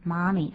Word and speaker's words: mummy: 可是英国人的 U 念作啊，也很接近美式的 mummy，他们念作 mummy。